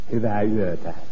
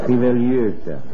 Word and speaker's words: Hyvää yötä: How do you say good night? – Hyvää yötä. Well, that's really hard. – Hyvää yötä. Hyvää yötä.